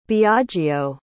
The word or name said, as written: biagio